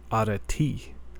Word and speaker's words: Arete: During that downtime, I looked up how to pronounce that word, and it is a Greek word, and it's pronounced arete. Arete.